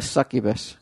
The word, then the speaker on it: succubus: Succubus. – Oh my God! I was gonna say that right? – You said it right the first time. So you corrected yourself to the wrong way.